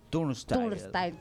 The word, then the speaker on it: Turnstile: Turnstile.